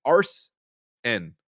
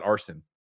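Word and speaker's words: Arsène: It's Arsen, not arson.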